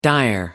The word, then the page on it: dire: American English